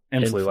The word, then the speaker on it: Influ: Influ, like influencer, influ.